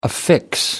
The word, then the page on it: affix: American English